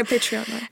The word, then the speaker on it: Patreon: Ouais, Patreon.